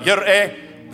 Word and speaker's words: Jireh: Jehovah Jireh, Yireh. There's the right pronunciation.